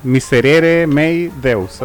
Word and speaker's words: Miserere mei Deus: No sé, está Miserere Mei Deus, así se llama, pero no sé si está bien pronunciado porque es latín.